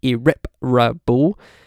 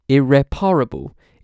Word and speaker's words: irreparable: Seems to be changing from four syllables with the main stress on the second, irreparable, to five syllables, the main stress on the third, irreparable.